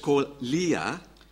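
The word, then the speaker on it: koelia (κοιλία): Is koelia. – Wird koelia ausgesprochen.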